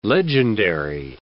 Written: legendary

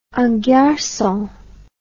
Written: en garcon

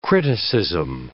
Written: criticism

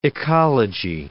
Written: ecology

